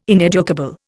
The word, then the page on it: ineducable: Indian